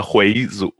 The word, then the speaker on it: Huizu: But what I'm really curious about is that you come from the Hui. Am I pronouncing it right or wrong? – Huizu. Yeah, Huizu. Yeah.